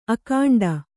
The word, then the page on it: ಅಖಂಡ: akhaṇḍa